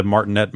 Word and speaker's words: Martinet: Okay, okay, maybe right, it is Martinet. Okay. – Yeah. So I feel like the Martinet fucking back and forth has been dogging us for decades.